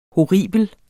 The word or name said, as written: horribel